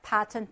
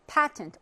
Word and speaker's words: patent: 자, although, 뭐이긴 하지만, it accomplished this objective in a very different manner than patent protection. 파텐트란 단어 좀 기억해 두시는 게 좋아요. 물론 여기는 지금 나와 있긴 한데, patent or patent 둘다 발음이 가능하고요.